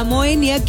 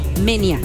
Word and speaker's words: Moenia: Y ya sin luego le cambiaron, te lo juro, a Moenia, que lo pronunciamos mal porque se pronuncia Menia.